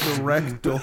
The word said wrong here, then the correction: reticle: did you just mispronounce the word? – Yeah, reticle, right? – Yeah, I meant to say reticle, and I was just reading.